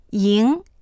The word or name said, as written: ying2